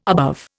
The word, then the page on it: above: Indian